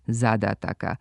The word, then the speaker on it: zadataka: jedino ispravan akcenat ove imenice zadataka, sa kratko uzlaznim akcentom na prvom slogu i dužinama na svakom sljedećem.